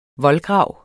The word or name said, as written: voldgrav